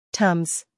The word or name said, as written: Tums